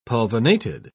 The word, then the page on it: pulvinated: British English